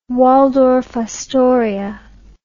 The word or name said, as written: waldorf astoria